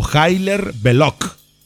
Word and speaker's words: Hilaire Belloc: O Hilaire sería. El imaginario de Hilaire Belloc. O Hilaire Belloc si quieres la pronunciación correcta, aquel escritor anglo-francés.